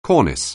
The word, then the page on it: cornice: anglais